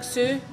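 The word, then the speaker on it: Hsu: And then Stephanie, and I'm not entirely sure how you say it, is it like Sue? – Yeah.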